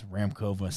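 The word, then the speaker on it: Šramková: And, yes, there's an S in front. It's Ramkova, silent S, I believe.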